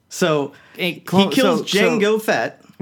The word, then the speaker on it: Django: Also, Django, it's a Django. – Oh, that's right. But the D is silent.